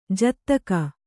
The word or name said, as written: ಜೊತ್ತಗೆ